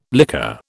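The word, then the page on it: lycée: British English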